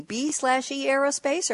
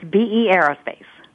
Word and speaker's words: B/E Aerospace: How do I pronounce your company? B/E Aerospace, or what's the pronunciation? – It's BE Aerospace. – BE Aerospace. There you go. It was the slash that threw me off.